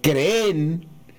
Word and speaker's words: creen: todos creen también con acento agudo en la E final, creen. ¿Es correcto pronunciar se cree con ese acento? ¿Y creen con ese acento, profesor? Nos pregunta Robinson Romero. – Robinson tiene razón. El presidente se equivocó en la pronunciación de esa palabra como se equivocan muchos colombianos. Porque creen que porque la palabra tiene doble E, que hay que acentuar la última E, que es aguda.